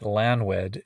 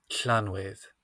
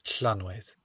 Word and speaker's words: Llanwedd: So the pronunciation, I believe, would be Llanwedd. – Right. – So it's like you put your mouth in the position for an L and then try to say an S. Llanwedd.